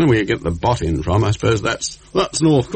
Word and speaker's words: Botham: Apropos pronunciation of cricketer's name, Botham. His name should be pronounced Botin. Should it? Now, it's Botham or Botham. He doesn't mind which.